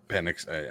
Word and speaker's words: Penix: Penix stiffened up, and I can't say Penix. I know that's how it's pronounced.